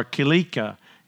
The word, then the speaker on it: Cilicia: Then I went to the districts of Syria and Cilicia, or Kilika is how it's said in Hebrew.